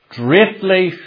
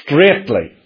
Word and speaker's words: straitly: Now that's a word you will come across many times in the Bible, straitly, but the spelling is special, and although we pronounce it the same as that which means a straight line, this is spelt differently from the word that refers to a straight line, and the spelling here tells us that the word doesn't mean a straight line, but it means tight.